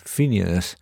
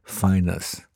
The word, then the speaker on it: Phineas: El pianista Phineas Newborn, yo siempre pensé que su nombre se pronunciaba Phineas, pero en las notas del disco él mismo explica que es Phineas.